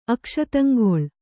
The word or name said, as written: ಅಕ್ಷತಂಗೊಳ್